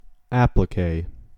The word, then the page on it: applique: American English